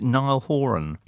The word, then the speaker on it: Niall Horan: Okay, but in the meantime, amuse yourselves with the one that came out today, which features, is it Niall Horan? – Yes.